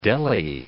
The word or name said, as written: deli